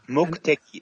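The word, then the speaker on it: Mokuteki (目的): Mokuteki is the way to pronounce that.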